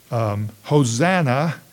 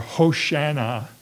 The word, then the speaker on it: Hosanna: And this word, Hosanna, or Hoshana, as it sometimes is pronounced in Hebrew, was prominent in these psalms.